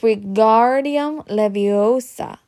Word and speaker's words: Wingardium Leviosa: Wingardium Leviosa, he shouted, waving his long arms about with a windmill. You're saying it wrong, Harry heard Hermione snatch. It's Wingardium Leviosa. Make the gar good and long.